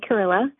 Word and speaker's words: Carrilla: My name is Renee Carrilla. It's easy to remember how my last name is pronounced because it rhymes with gorilla.